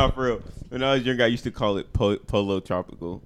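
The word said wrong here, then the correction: Pollo Tropical: until somebody was like, you gotta say Pollo.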